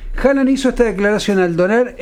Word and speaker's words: Haaland: Escucha, Erling Haaland, que ayer nos decía en noruego se pronuncia Haaland. Haaland. Pero bueno, uh-huh. Vamos a decirle Haaland.